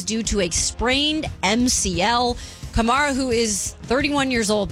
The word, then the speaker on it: Kamara: It's Kamara. – Yeah, Alvin Kamara. – Not Kamara.